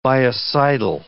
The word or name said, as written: biocidal